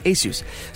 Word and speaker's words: ASUS: A lot of people mispronounce that name. I think we get it right, ASUS.